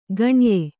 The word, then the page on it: ಖಣಯ: khaṇaya